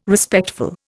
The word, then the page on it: Respectful: Indian